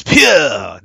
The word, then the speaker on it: Pugh: Florence Pugh, Florence Pugh. – Florence Pugh! No, that's how I say her name. Florence Pugh! Pew, pew!